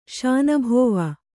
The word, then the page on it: ಶಾನಬಾವ: kannada